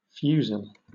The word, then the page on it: fusil: British English